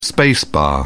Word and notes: space bar: British English

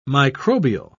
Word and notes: microbial: British English

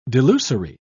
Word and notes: delusory: British English

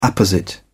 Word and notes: apposite: British English

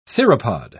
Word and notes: theropod: British English

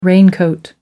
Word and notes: raincoat: American English

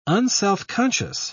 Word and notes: unselfconscious: British English

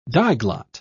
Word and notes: diglot: British English

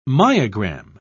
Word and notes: myogram: British English

